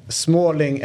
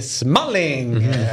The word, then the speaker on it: Smalling: Typ Småling är ju Smalling.